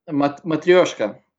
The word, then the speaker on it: Matryoshka: Matryoshka? – Matryoshka, yeah. – Matryoshka. – it's a hard word to say. – They have a beer called Matryoshka.